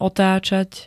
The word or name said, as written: otacat